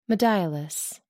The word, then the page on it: modiolus: American English